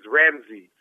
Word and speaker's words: Ramses: The correct pronunciation of my name is Ramsey, like Seize Candy or something like that, or Cease.